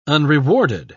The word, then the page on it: unrewarded: British English